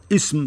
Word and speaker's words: اسم: اسم تكتب وتنطق ايضا بغير همزه فاذا اتت كلمه اسم في بدايه الجمله فلا بد من نطق همزه في اولها لكنها في كل الاحوال لا تكتب الاسم الوظيفي اسم الوظيفة التي يشغلها الموظف، وأما المسمى الوظيفي فهو شخص الموظف نفسه.